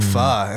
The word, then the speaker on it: pho: to pho, and now that's like one of my favorite things.